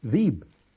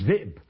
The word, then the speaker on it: ذئب: أبو جعفر المدني، سواء فاء كلمة عين كلمة لام كلمة مش هينطقوا، فيقول ايه مؤمنون، ويقول بير مش بئر، ذيب مش ذئب، يقول كاس مش كأس، تمام؟